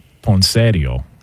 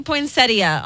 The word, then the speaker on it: poinsettia: Well, the in-laws would call it poinsettio. – Oh, gosh. – Okay. What up? You know what I'm saying? – Oh, God. – Can we get the proper pronunciation on this? – It does say poinsettia online.